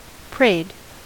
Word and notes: prayed: American English

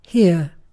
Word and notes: here: British English

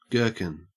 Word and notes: gherkin: Australian English

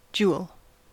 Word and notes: jewel: American English

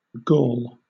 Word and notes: gall: British English

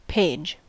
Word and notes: page: American English

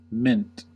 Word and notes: mint: American English

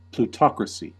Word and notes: plutocracy: American English

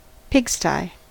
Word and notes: pigsty: American English

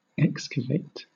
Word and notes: excavate: British English

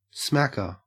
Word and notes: smacker: Australian English

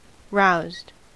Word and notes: roused: American English

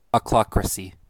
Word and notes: ochlocracy: American English

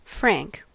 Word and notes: frank: American English

